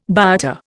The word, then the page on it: biota: Indian